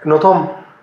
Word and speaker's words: Nothomb: ¿Y cómo se pronuncia? – No Tom.